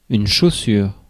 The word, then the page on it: chaussure: [yn.ʃo.syʁ]; France